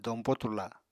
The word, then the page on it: dompo turla: apatani